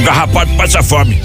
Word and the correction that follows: carrapato: Não é garrapato, é carrapato.